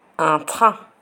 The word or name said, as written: un train